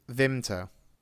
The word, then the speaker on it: Vimto: Vimto.